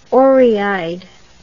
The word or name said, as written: orieeyed